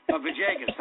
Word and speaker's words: Villegas: Is that how you pronounce his name? Camilo Villegas. Vijaygas, that's right.